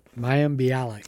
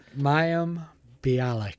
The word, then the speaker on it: Mayim Bialik: And I did not know this until I was looking it up, but Maleem Balik, am I saying that wrong? The girl that played Blossom? – Mayim Bialik. – Thank you so much. I can never pronounce her name right. Say it again.